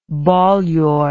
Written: Bolyai

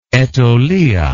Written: Aitolia

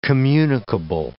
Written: communicable

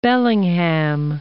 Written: Bellingham

